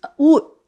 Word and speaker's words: oud: And oud, that's how it's pronounced in Arabic, is one of the most distinguishing flavors of the Aleppian cooking.